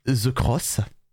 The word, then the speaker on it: The X: Donc, entrer dans le top 10, l'opening de Tales of Zestiria The Cross. Ça, ça, ça, se dit The Cross, mais ça s'écrit The X.